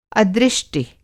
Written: अदृष्टि

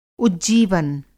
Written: उज्जीवन

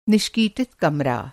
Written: निष्कीटित

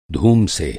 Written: धूम

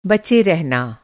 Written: बच

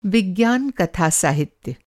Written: विज्ञान कथा साहित्य

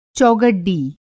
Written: चौगड्डी

